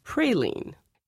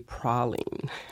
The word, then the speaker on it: praline: For example, oftentimes you hear people say praline. In New Orleans, we say praline.